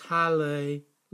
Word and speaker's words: Hallelujah: Phonetically, in Hebrew, it is Hallelujah, literally, praise to Yah, from the Tetragrammaton YHWH, the placeholder for the unspeakable name of God.